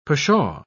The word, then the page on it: pshaw: British English